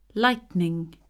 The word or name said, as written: lightning